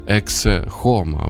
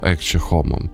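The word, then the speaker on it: Ecce Homo: Має назву «Ексе Хома» або «Екче Хома». Залежить від того, як читати латинь, як вимовляти, власне, ці тексти латинською мовою.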